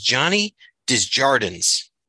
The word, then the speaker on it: Johnny Desjardins: Our guest today is Johnny Desjardins.